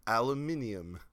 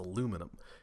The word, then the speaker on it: aluminum: Yeah, the British like to either take away or add syllables depending on the word. – Like... aluminium. – Aluminium. No, it's aluminum.